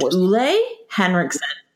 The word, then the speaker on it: Ole Henriksen: I just learned that this is pronounced Ole Henriksen. Yeah, because he's from Copenhagen, you know, like the whole huggy, whatever.